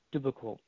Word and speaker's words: duplicable: You know, and the other thing too is holding big events technically is not duplicable. Did I say that right? Duplicable. – Correct.